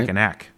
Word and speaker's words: Mackinac: Macaroon, I think it's pronounced. Macaroon, right? – Mackinac.